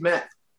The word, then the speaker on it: Kmet: I want to talk about Kmet. That's how you say his name. – Different guy. – I think it's Met, you know?